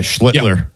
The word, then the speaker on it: Schlittler: I've seen probably Skeens pitch twice on TV, and I've seen, how you say his name, Schlittler? – Yeah, Schlittler. Yeah, it's a tough one.